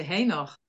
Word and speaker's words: Enoch: I see two pronunciations for that word. I say Enoch, but Enoch, which is correct, I don't know. – We say Henoch. – Is that right? – We have an H in front of it.